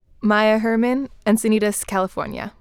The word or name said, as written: Maya Herman